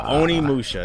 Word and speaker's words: Onimusha: Onimusha. – Onimusha.